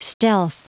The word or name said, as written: stealth